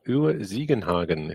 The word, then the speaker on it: Uwe Ziegenhagen: So tonight we have Uwe Siegenhagen, if I pronounce that correctly. – Yeah, that is more or less correct, yeah.